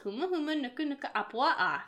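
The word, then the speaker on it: Huma huma nuku nuku apua'a (humuhumunukunukuāpuaʻa): Huma huma nuku nuku apua'a. – The hard part is the ah.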